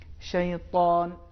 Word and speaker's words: شیطان: تو اس کا صحیح تلفظ اس طریقے سے ہے شیطان اعوذ باللہ من الشیطان الرجیم. اس میں یہ موٹے انداز سے ادا ہوگا.